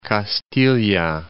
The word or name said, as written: Castilla